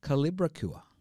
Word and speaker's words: Kolibricura: So the name is Kolibricura. Did I get that right? – Pretty much. Kolibricura, yes. It's pronounced however you want to really pronounce it.